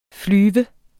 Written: flyve

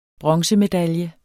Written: bronzemedalje

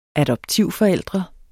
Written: adoptivforældre

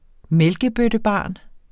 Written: mælkebøttebarn

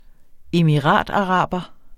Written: emirataraber

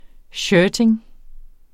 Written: shirting

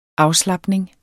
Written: afslapning

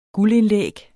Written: guldindlæg

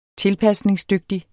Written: TILPASNINGSDYGTIGT

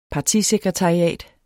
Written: partisekretariat

